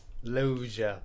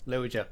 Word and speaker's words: Loggia: Jag vet inte om man ska uttala efternamnet riktigt där. – Loggia. – Loja. – Loja.